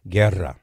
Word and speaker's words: Guerra: When you have a double R, you have to trill the R. Guerra.